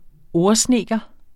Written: ordsnedker

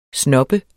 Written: snob

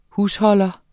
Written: HUSHOLDERNE

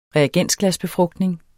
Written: reagensglasbefrugtning

